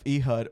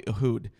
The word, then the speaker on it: Ehud: But is the story of Ehud or what is probably Ehud but most people call it EHUD is what I've heard it pronounced as. – Because that's Americanized that's way of saying that.